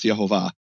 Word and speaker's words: Yehovah: Instead, they have Shvach Olam Patach, which, guess how that's pronounced in Hebrew, Michael? That's also pronounced Yehovah.